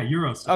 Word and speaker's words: GyroStep: And I've implored you guys when Ty came on, check out, is it you guys call it GyroStep? I call it GyroStep. – Yeah, GyroStep. – Okay, cool. It's big. Like the move.